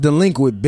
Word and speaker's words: DLEQUINT: It's pronounced delinquent, bitch.